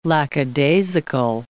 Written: lackadaisical

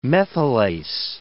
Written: methylase

